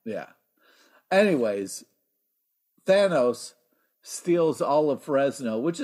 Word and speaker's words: Thanos: Or it can be pronounced Thanos, however you want to say it. You know what I mean? – Yeah. You roll your own. Here's the thing. That's why I think Thanos is so mad, because everyone keeps saying his name in a different way. – He's pronouncing his name, yeah. – I know. That's what you're doing. – Yeah. Or maybe you're doing it. – Thanos like anus.